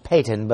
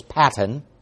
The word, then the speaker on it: Patton: Patton, it looks like it's pronounced Peyton, but it's Patton,